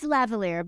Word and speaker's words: lavalier: How do you pronounce it? Lavalier? – It's lavalier